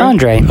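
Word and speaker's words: Andrei: Yeah, it's Andre.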